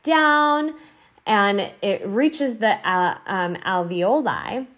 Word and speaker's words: alveoli: And by the way, I said it wrong in the webinar, but I relearned how to say the word. It's alveoli.